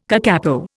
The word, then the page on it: kakapo: Indian